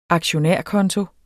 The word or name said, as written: aktionærkonto